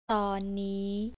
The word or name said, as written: tonnii